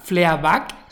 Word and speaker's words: Fleabag: Se denomina eh, Fleabag, Fleabag, se escribe, F-L-E-A-B-A-G.